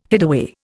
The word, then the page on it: hideaway: Indian